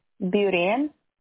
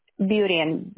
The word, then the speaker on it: Burien: B-O-T-H-E-L-L. – Okay. – Kenmore. – All right. – Federal Way. Burien. – What was that last one? – Burien. B-U-R-I-E-N.